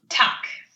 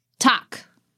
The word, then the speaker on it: tak: How do you say thank you in Danish? – Tak. – Tak.